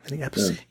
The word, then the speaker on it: Appice: It's Vinny Appice. – Yeah, Vinny Appice. – Oh, okay. – Yeah. Appice? Is that how you say it? – I think so. Vinny Appice.